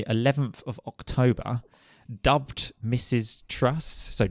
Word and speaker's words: Truss: She wasn't actually Prime Minister long enough for me to find out how to correctly pronounce the name. How is it? – Truss, right, okay.